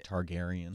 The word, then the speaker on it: Targaryen: Did I say that right? Targaryen? – You did, yeah.